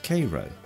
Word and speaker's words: Cairo: If the train had run to schedule, we'd have passed through a town spelt like Cairo, but pronounced Cairo, a river port at the junction of the Ohio and Mississippi rivers.